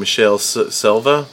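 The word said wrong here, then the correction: Michele Soavi: Mihaela Soavi. – Yeah, I always say it wrong. I'm never going to be able to say it right.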